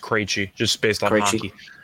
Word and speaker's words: Krejci: It's pronounced Krejci, so I'm assuming it's pronounced the same way.